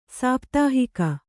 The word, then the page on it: ಸಾಪ್ತಾಹಿಕ: sāptāhika